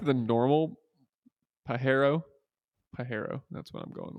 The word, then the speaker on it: Pajero: Pajero? Pajero? – Yeah.